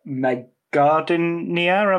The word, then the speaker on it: Meg Gardiner: Yeah, so he co-authored it with a novelist called Meg Gardiner. Am I pronouncing that? – That's right, yeah.